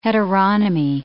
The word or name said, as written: heteronomy